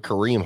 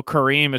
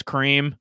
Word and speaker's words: Kareem: So Kareem is Cream. – Cream and like Cream, yeah.